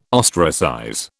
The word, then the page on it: ostracize: British English